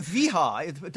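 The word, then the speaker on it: Wiha: I'm thinking it sounds more like a sort of Southern American Weeha. I don't quite know. I said Viha. W will be pronounced as a V sound, so Viha, oh, yes.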